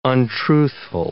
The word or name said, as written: untruthful